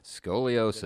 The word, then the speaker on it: scoliosis: Scoliosis.